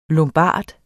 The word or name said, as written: lombard